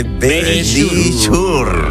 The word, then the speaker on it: Verisure: Dilo tú, que pronuncia mejor. – Sí, venga, dilo. – Nuestros amigos de Verisure. – Ahí lo habéis dicho divinamente.